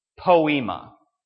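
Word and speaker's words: poiema: So here's the Greek word. Poema.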